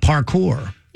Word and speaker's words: parkour: What about parkour? Am I saying that right? – Yeah.